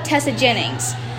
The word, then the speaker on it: Tessa Jennings: Or if you don't have that, or if you don't want to look that up, just look up Tessa Jennings. My name is spelled T-E-S-S-A space J-E-N-N-I-N-G-S.